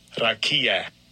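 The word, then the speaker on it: rakia (רקיע): The word that is used for firmament, I think it's rakia, is how you say it. Let me check that. – Strong's H, 7549. Rakia. Rakia. – See, that guy knows how to say it.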